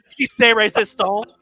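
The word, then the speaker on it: pièce de résistance: De la Resistance? Probably said that wrong. Piece de resistance.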